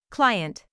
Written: client

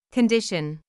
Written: condition

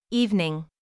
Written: evening

